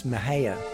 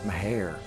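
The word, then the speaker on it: Mexia: Some of you all may know that as Mahaya. It's pronounced Meharre.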